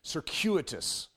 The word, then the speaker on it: circuitous: But circuitous technically is the proper way of saying it.